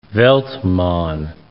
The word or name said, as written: Veltman